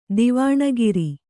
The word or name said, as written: ಧ್ವನಿಕಾರ